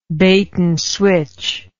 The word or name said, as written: bait and switch